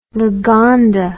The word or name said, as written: Lagonda